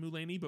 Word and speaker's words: Mulaney: I feel like I want to use the word zany when I'm describing him, partly because it rhymes with Mulaney, but partly because that's kind of like his energy.